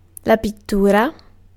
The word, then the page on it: pittura: /pit.ˈtu.ra/